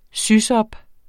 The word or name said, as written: sysop